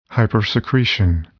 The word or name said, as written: hypersecretion